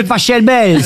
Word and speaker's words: Pachelbel: Catelloni de Pachelbel. – S'il est allemand, ça se dit Pachelbel.